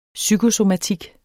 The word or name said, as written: psykosomatik